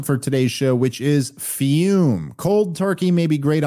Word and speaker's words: Fum: We're talking about our sponsor, Fume, pronounced Fume, spelled F-U-M.